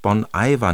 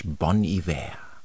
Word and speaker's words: Bon Iver: Weiter jetzt mit Justin Vernon, der sich Bon Iver nennt, geschrieben B-O-N-I-V-E-R. Eigentlich dachte ich immer, ja, Bon Iver, aber mit falschem Französisch, benannt nämlich nach Bon Iver, guter Winter. Und er spricht sich tatsächlich in seinem Künstlernamen Bon Iver, geschrieben aber wie Bon Iver.